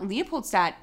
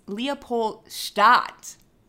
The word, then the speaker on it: Leopoldstadt: At Leopoldstadt, yes. – Which I listened to him say the other day, it's not Leopoldstadt, it's Leopoldstadt.